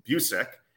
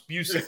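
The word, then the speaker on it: Bucyk: I have to correct your pronunciation. You're clearly not a hockey fan. That's okay. It's Busick. It's named after a former hockey player, Johnny Busick.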